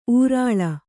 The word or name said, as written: ಉರಾಳ